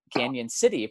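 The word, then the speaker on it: Cañon City: Canyon City, pronounced Canyon because of the tilde in Spanish.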